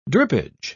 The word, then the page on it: drippage: British English